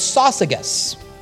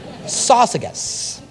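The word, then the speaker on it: Syzygus: So, companion is a name, and the name is Sosigus. I remember it because it's like sausages with a hard G. Sosigus.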